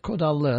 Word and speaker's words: Kodallı: Nevit is spelled N-E-V-I-T. Kodallı is K-O-D-A-L-L-I.